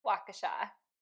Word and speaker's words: Waukesha: Waukesha. – Waukesha, Wisconsin.